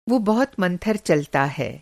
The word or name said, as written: मंथर